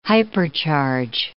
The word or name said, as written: hypercharge